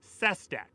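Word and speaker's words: Sestak: Sestek? I don't know. – But he pronounces it Sestek.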